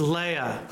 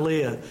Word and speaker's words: Leah: Got to say that right in Hebrew. It's Leah, not Leah. Leah.